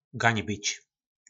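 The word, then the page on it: Gaňbič: nářečí Po našimu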